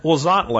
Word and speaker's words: Huauzontle: But what I'm going to plant more of is a plant called wasantle. And I've talked about it before. But man, this is a plant you really should be growing. Wasantle is, and it's spelled H-U-A-U-Z-O-N-T-L-E.